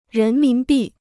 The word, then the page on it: 人民币: rén mín bì